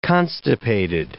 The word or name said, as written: constipated